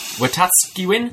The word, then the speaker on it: Wetaskiwin: I'm going to butcher this name. Wetaskiwin? Wetaskiwin? – Nice. – You're Canadian. – Yeah. – Is that right? – Yeah, it sounds perfect.